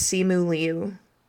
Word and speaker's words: Simu Liu: I looked up how his name is pronounced, and according to YouTube, it's Simu Liu. – Okay, so I almost had it. I just didn't enunciate the I in his last name.